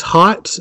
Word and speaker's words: Hotz: Okay, that one is by Tomasi and I guess Hotz is how you pronounce his name, H-O-T-Z.